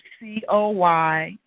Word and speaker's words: Coylette: Anyone that would like to connect with me, my Facebook page is Coylette James. My name is spelled C-O-Y. – You're fading out. – My name is spelled Coylette.